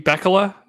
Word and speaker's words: Bekele: Please pronounce his name. – Is it supposed to be Bekele? Is that what the pronunciation guide said? – Yes.